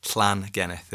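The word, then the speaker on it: Llangennith: And I'm living in a place called Llangenneth, and that's L-L-A-N-G-E-N-N-I-T-H. So, yeah. Llangenneth, and there's is the way you pronounce the words with two L's at the beginning. Llan.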